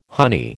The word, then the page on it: honey: American English